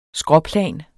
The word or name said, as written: skråplan